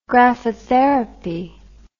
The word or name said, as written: graphotherapy